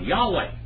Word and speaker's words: Yahweh: They say Yahweh. This is a correct pronunciation of the name of God, Yahweh.